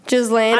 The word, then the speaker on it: Ghislaine: I think Ghislaine. – Ghislaine.